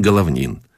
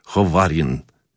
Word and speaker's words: Головнин: Наконец, в окно ближнего к нам строения назвали меня по имени «Капитан Головнин», но японцы фамилию мою произносили почти как «Ховарин» и велели ввести.